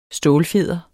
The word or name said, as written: stålfjeder